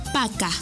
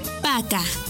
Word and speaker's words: пока: Para finalizar, la palabra adiós se dice paca. Paca. Y se escribe paca. P A K A. Paca.